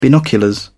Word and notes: binoculars: British English

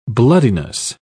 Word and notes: bloodiness: British English

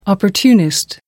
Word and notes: opportunist: American English

